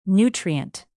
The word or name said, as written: nutrient